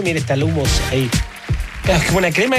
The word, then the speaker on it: hummus: Quisiera referir que el hummus se pronuncia hummus.